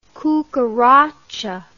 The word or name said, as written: cucaracha